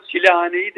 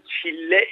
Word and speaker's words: çille: Bu arada çilehaneyi de yanlış telaffuz ederiz genelde. Çile evi anlamına gelir çilehane. Oysa tasavvufta çile evi diye bir şey yoktur. O aslında çille evidir.